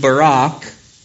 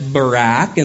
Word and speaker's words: Barak: Verse 6, she sent and summoned Barak. That is the proper Hebrew pronunciation, but English speakers usually pronounce that as Barak, and that's fine as well.